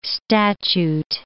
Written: statute

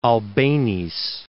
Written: Albeniz

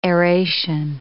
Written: aeration

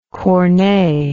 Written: Corneille

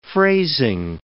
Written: phrasing

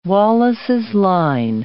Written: Wallace's line

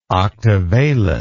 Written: octavalent

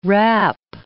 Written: rap

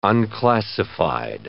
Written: unclassified

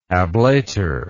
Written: ablator

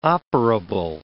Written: operable

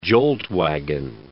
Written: jolt-wagon

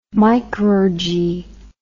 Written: micrurgy

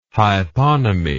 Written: hyponymy

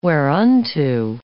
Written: whereunto

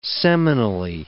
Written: seminally

